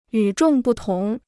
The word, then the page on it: 与众不同: yǔ zhòng bù tóng